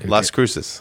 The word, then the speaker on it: Las Cruces: La Cruces, New Mexico. I'm not sure if I'm saying that right, but you're not. – I mean, Las Cruces.